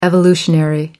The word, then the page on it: evolutionary: American English